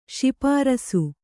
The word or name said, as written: ಶಿಪಾರಸ್ಸು